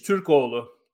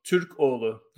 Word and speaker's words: Türkoğlu: It's Türkoğlu. Türkoğlu. The G is silent because it has a little, little thing on it.